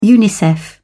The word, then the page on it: unicef: British English